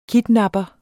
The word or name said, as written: kidnapper